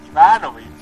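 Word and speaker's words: Ivanović: Ivanovich.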